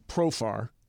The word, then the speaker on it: Profar: Jerks and Profar. I'm saying it right? – Yeah.